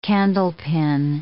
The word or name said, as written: candlepin